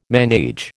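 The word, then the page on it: ménage: American English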